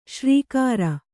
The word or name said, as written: ಶರ್ಕರೆ